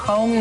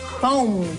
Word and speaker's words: Houm: Sí de Home. ¿Cómo se escribe? H-O-U-M. Para todas las personas que dicen home, así como home, no, home.